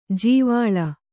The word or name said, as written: ಜೀವಳ